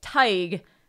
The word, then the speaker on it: Tig: Tig. Rhyming with tiger without the R.